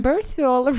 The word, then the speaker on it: Birthwell: Det finns en grej som heter Birthwell. – Ja, det är så uttalar man det. Mm. – Birthwell Reality.